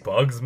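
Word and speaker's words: Muggsy Bogues: They steal the talent of real-life New York Knicks players Charles Barkley, Ahmad Rashad, Patrick Ewing, Muggsy Bacchus. I'm sorry if I mispronounced that. Muggsy Bacchus. – Muggsy Bugs, maybe? – Bugs, yeah. And Sean Bradley.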